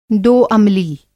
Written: दो-अमली

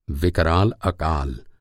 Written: विकराल

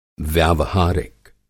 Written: व्यावहारिक

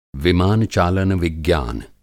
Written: विमान चालन विज्ञान

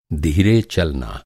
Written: धीरे

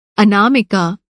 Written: अनामिका

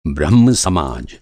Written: ब्रह्म समाज

